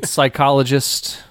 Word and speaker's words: psychologist: Words containing a silent P. – Pterodactyl. Can I also say pteranodon? – No. – psychologist, psychiatrist.